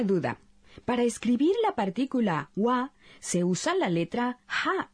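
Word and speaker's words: wa (は): Se pronuncia wa, pero se escribe ja.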